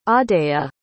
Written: audeia

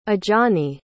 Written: ajani

